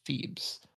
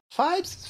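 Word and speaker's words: Phibes: 1971's Vincent Price starring classic horror film, The Abominable Dr. Phoebes. Fibes? – Mm-hmm. Rhymes with fibes.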